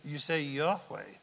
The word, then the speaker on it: Yahweh: Capital L, O-R-N-D, so that's Jehovah God. What is Jehovah God? How do you say Jehovah God in Hebrew? You say Yahweh. Those are, in Hebrew, that would be the words, the letters Y-H-W-H.